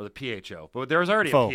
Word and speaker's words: pho: Yeah, even though it's pho. I don't want to be that guy, but proper pronunciation is important.